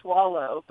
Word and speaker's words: Soileau: Well, my new last name will be Swallow, but it's spelled S-O-I-L-E-A-U. So it looks like Swole.